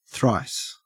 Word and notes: thrice: Australian English

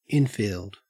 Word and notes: infield: Australian English

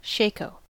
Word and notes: shako: American English

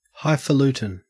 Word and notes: highfalutin: Australian English

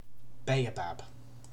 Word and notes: baobab: British English

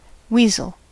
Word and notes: weasel: American English

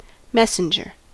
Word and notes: messenger: American English

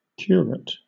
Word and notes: curate: British English

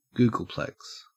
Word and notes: googolplex: Australian English

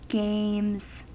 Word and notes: games: American English

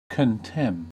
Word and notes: contemn: American English

